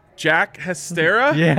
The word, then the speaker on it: Jack Hestera: You're gonna have to help me with the pronunciation here. Jack Hestera? – yeah, you're right.